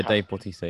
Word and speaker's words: Bautista: Yeah, Dave Bautista, yeah. – Dave Bautista.